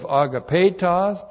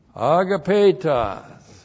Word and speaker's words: agapetos: This is the vocative of agapetos plus the present active indicative of poieo plus the accusative singular pistos. Agapetos. A-G-A-P, long E-T-O-S, agapetos.